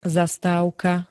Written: zastávka

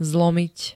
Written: zlomiť

